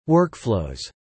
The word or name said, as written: workflows